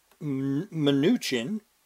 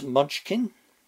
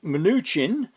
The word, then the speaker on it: Mnuchin: It can't be helping that President Trump's Treasury Secretary, Steven Mnuchin, do you have any idea how hard it is to not pronounce that as Munchkin? Secretary Mnuchin